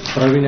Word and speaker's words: Pravin: Praveen M. – Patel. Can you spell that first name for me? – P-R-A-V-I-N. Patel. P-A-T-L.